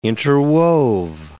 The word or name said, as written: interwove